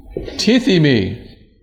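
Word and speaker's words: tithimi (τίθημι): So, in case you like any of this kind of stuff, the Greek word is this word here, tithimi. All right. And in English, we would spell it like this. That's a long E.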